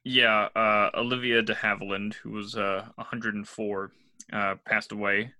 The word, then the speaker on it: de Havilland: De Havilland is how you say it. It's not a hard one.